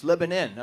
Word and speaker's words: Lebanon: They don't like it pronounced like that. I went up there. It's Lebanon. I'm like, well, you ought to put an I in there then.